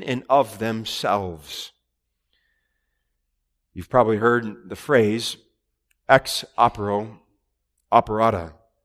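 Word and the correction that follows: ex opere operato: I said that wrong. Ex opera operato.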